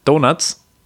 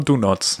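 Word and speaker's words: Donots: Wie hättest du sie ausgesprochen? – Sprich aus. – Donuts? – Nee, ja, weiß ich nicht. Ist ja kein Doppel-N. – Ne? Schon Do Nots.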